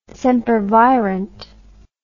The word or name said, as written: sempervirent